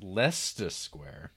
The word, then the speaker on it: Leicester Square: If you get off at Leicester Square tube station, written as Leicester Square, but pronounced Leicester Square, you're only 5 minutes walk from Piccadilly Circus, stroll west.